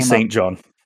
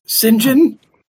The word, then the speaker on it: St. John: I listened to a bunch of pronunciations before this, and it was fairly clear that a few places say St. John, and a lot of other places just say St. John.